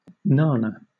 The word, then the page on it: nana: British English